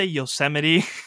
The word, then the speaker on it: Yosemite: She's like, "Are you trying to say Yosemite?" I was like, "Is that how it's pronounced?" – That's great.